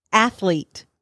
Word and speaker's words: athlete: Okay, so athlete. – Yeah? – Two syllables. – Oh, really? – Athlete. It's not athlete.